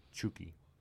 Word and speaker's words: Chucky: Well, that's the if you pronounce it with a Mexican accent. – Okay. – Yeah, Chucky. Chucky Lozano.